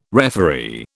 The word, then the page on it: referee: British English